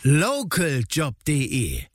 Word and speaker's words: Localjob.de: Ja, aber die Betonung mehr auf Local. – Localjob.de.